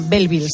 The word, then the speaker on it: Belleville: Y es Bienvenidos a Belleville, ¿se dice así? – Sí, sí, sí, lo ha dicho, fenomenal. El